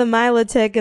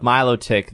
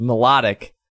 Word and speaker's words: Milotic: Because probably for the entire existence of Milotic, they thought it was pronounced Melodic, and now they're just deeply upset that it's pronounced the way they don't want it to be pronounced. – I mean, it's pronounced how it's said. I mean, spelled.